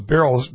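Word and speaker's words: Beyrle: That's Joseph Beryl. Beryl is spelled B-E-Y-R-L-E. B-E-Y-R-L-E.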